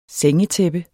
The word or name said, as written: sengetæppe